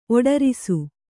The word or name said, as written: ಒಡರಿಸು